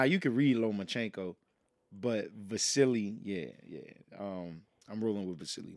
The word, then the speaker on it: Vasily: Wait, say that name again. – Vasily. – You saying it right? – Yeah. – Okay. – Yeah, Vasily Lomachenko.